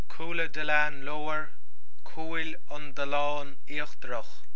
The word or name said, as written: Cúil an Dalláin Íochtarach